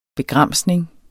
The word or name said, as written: begramsning